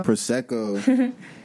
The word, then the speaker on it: Prosecco: Prosecco.